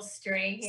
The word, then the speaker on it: Strahan: Michael Strahan.